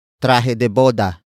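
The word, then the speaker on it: trahe de boda: And that object is traje de boda. Traje de boda. Traje de boda. Traje de boda is spelled as T-R-A-H-E space D-E space B-O-D-A.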